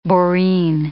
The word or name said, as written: boreen